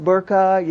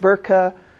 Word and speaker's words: burqa: so you will hear that word pronounced burqa, you will hear it burqa.